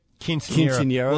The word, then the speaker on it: Quinceañera: Quinceañera.